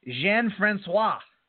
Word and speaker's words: Jean-Francois: I had to look it up. It's French. Ricky Jean-Francois.